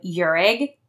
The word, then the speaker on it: Urig: And Claire is spelled C-L-A-I-R-E, last name Urig, U-R-I-G,